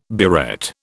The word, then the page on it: burette: British English